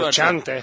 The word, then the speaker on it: Frusciante: Eu fiz a, a, pronúncia aqui da Little Italy, viu? – Frusciante.